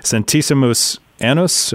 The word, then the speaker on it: Centesimus Annus: It was, and I'm not sure if I say this correctly, Centesimus Annus. Am I saying that right? Do you know this document? – It sounds correct, yes.